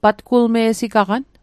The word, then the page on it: patgulme'sigaqan: Mi'gmaq